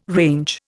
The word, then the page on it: range: Indian